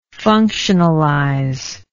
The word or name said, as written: functionalization